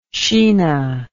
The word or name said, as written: Sheena